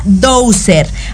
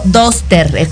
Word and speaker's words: Dozer: porque el 12 se llama Dozer. Ale Domínguez también lo dijo, pero eh, dijo Doster. – Ah, eh, siempre se confunden. Doster, Frozen, Buster pasa por todas. Se escribe Dozer, D-O-Z-E-R.